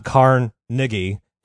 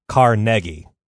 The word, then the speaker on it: Carnegie: All right, one of the first things I want to say about Andrew Carnegie is we've all been mispronouncing his name. We've been splitting the syllables up weird. It's not Carnegie. It's Carnegie.